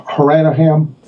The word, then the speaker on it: Hanrahan: It's Irish, right? So Hanrahan. Hanrahan. It's Hanrahan. Hanrahan.